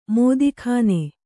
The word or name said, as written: ಮೋದೀಖಾನೆ